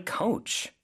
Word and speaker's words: coach: Well, I learned so much from my coach. 코치님께 정말 많이 배웠어요. – 아, 코치님이 my coach가 되네요. 그렇죠? coach 발음이 조금 다르죠? 그래서 오 발음을 그 길게 해주시면 됩니다.